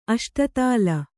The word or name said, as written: ಅಷ್ಟದಳ